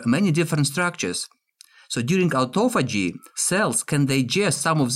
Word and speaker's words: autophagy: So what is autophagy? Phagy? Autophagy? Is that how you say it? – Autophagy. – Autophagy, okay.